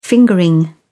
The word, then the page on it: fingering: British English